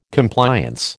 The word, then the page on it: Compliance: American English